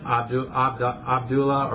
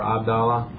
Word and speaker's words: Abdullah: And you just talked about Muhammad's father was named, how do you say that? – Abdullah or Abdallah, you know.